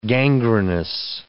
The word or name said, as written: gangrenous